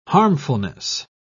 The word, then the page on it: harmfulness: British English